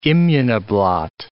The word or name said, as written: immunoblot